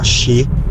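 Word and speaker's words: shi (四): considerato che porta sfiga perché si pronuncia in, anche in altri modi, ma tra i modi anche, si pronuncia shi.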